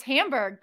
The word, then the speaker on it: Hamburg: It's Hamburg. That's how the Germans say it. – I would have thought it was that was the American way. – We say it like all fancy, but I think it's actually the less fancy version. It's like hamburger, but without the er.